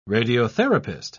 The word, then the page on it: radiotherapist: British English